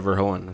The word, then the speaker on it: Verhoeven: Directed Paul Verhoeven. I'm probably saying that so wrong. – Paul Verhoeven, that's how I would pronounce it. Anyways, we're not talking about RoboCop. Yeah, that's a Verhoeven.